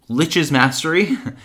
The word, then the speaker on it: Lich's Mastery: Oh, I'm sorry. I was saying it wrong. Lich's Mastery.